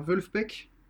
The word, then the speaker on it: Vulfpeck: Donc c'est un groupe de funk, ça s'écrit V-U-L-F-P-E-C-K.